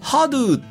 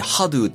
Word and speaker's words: XADO: ハドゥっていうらしいんですが、メーカー名はね。うん、ウクライナのメーカーなんですよ。うん、はい。 – XADO って書いてハドゥって読むらしいんですけども、